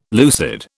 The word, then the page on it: lucid: British English